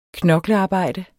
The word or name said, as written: knoklearbejde